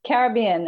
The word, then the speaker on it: Caribbean: Caribbean.